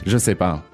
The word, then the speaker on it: je sais pas: Je sais pas.